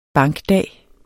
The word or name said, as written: bankdag